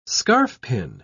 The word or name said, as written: scarfpin